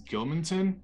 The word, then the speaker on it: Gilmanton: And that's in, I don't know how to pronounce this, Gilmanton, New Hampshire? – Yes.